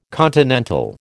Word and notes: continental: American English

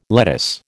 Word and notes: lettuce: American English

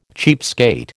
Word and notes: cheapskate: American English

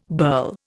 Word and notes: burl: Indian

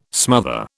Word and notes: smother: British English

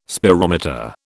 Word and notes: spirometer: British English

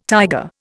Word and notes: tiger: Indian

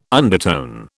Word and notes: undertone: British English

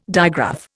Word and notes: digraph: Indian

